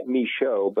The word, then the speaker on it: Michaud: His name is Stephen, S-T-E-P-H-E-N. The last name is spelled M-I-C-H-A-U-D. Michaud is a pronunciation. That's what my producer tells me. Stephen, do I have that pronunciation right? – You're pretty close. It's more like Michaud, but I answer to everything, so that's fine. – Michaud, okay. Right. But it's spelled M-I-C-H-A-U-D.